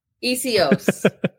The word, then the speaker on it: Essihos: Well, it's you can say Essihos or you could say Essihos. I just do less hos and more os. – Essihos.